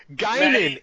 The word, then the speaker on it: Guinan: Guinan. It was Guinan. It was fucking Guinan. Maddie, you were pronouncing it wrong.